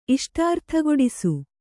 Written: ಇಷ್ಟಾರ್ಥಗೊಡಿಸು

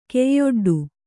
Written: ಕೈಯೊಡ್ಡು

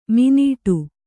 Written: ಮಿನೀಟು

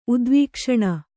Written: ಉದ್ವೀಕ್ಷಣ

